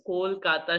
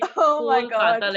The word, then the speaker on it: Kolkata: It's Kolkata. You got to say Kolkata like a true bong. You're going to have to like make really, you have to like accentuate your O's. Come on, Kolkata. – Kolkata. – Yes. Okay. See the A, the Ko and the A, Kolkata. That's how you say it.